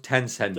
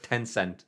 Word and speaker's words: Tencent: It's pronounced Tencent. – Yes. – Not, I know it looks like it's spelled Chinese government, but it's pronounced Tencent.